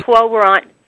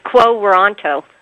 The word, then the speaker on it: Quo Warranto: Quo, is it pronounced Quo Warranto? – Yeah. – Is that how you... – Quo Warranto. Yeah, it's weird. It's Quo Warranto.